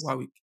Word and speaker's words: Warwick: Is it Warwick or Warwick? – Warwick.